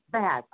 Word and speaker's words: Baz: B-A-Z. – Baz.